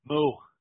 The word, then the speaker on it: Moo: Moo. – M-O-O, yeah. – Like a cow? – Yeah, M-O-O, yeah. Yeah, it's about as bad as, I wish I was joking. Rhymes with boo.